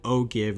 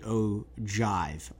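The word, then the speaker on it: ogive: But I've mentioned before an O-give, which is the width of a bullet, and I want to correct myself and say it is the O-jive, Oscar-jive, but it's spelt G-I-V-E, but pronounced with a J instead of a G.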